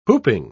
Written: whooping

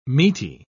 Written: meaty

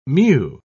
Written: mu

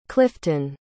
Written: clifton

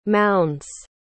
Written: mounds

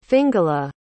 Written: fingula

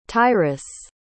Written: tyrus

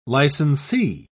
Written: licensee

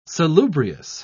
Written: salubrious

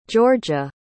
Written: jorja

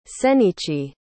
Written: senichi

